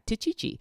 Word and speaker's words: Techichi: called a Tichichi, and they spell it T-E-C-H-I-C-H-I, Tichichi, which I think is a fabulous name for a dog.